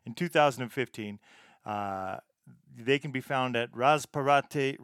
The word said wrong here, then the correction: Razzapparte: Razzapparte on both Facebook and Instagram, and that is spelled R A Z Z A P P A R T E.